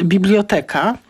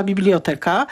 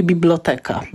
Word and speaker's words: biblioteka: Otóż jeszcze nie wiemy, wszyscy wiemy, o jakie słowo chodzi, ale wyraz ten wywodzi się z greki, w którym ma akcent na przedostatnią sylabę i dlatego wzorcowa, czyli taka najbardziej zalecana wymowa to jest biblioteka, ale również, można powiedzieć, nie jest błędna wymowa biblioteka na zasadzie skojarzeń z tymi wyrazami na eka, ika i tak dalej, ale na pewno nie biblioteka.